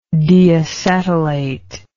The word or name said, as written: deacetylation